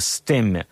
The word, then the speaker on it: Стемме: Это режиссерский дебют шведского художника по сцене и мужа Нины Стемме. Иногда ее фамилию произносят и как Штемме.